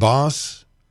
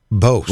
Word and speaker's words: Bos: Maybe it's Bose. – There's no E at the end. It's B-O-S. – B-O-S, just B-O-S. Yeah. Not Boss, Bose. – Well, I'm guessing Boss would be B-O-S-S, but I could be wrong.